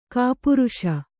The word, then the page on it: ಗೋಪರಸ: gōparasa